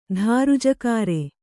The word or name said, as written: ಧಾರುಜಕಾರೆ